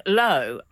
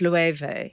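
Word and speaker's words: Loewe: Frank said. Oh, low. And I said, no, it's Lueve.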